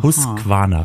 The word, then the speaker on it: Husqvarna: Also ich kann dich beruhigen, Holger, Husqvarna hast du vollkommen richtig ausgesprochen.